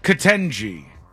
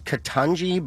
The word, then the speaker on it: Ketanji: I think it's pronounced Ketanji, but either way, Jackson would have sufficed.